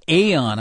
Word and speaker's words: Aon: How about this company, Aon? Am I pronouncing that correctly? It's spelled That's correct. – A-O-N, another insurance broker, and they're tied up in this as well.